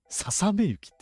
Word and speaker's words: 細雪: ささめ雪って読むのすごい。さ雪。足掛け、丸 10 年。